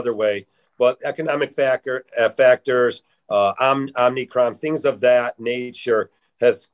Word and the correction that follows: Omicron: Omicron. And please. Omicron. Make- yes. – Omicron, not Omnicron, but it's okay.